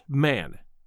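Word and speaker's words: Mahon: He had a Roman nose, a snow-white long beard, and his name, as I remember, was Mahone. But he insisted that it should be pronounced Man.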